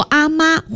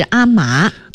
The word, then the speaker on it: 嬷: 还有认为这个阿嬷呀，应该写作就是写法上，把那个阿嬷的嬷写成一个女字旁，一个麻烦的麻啊，读作阿妈或者是阿嬷。